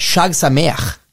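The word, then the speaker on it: Chag Sameach: Shag Sameach.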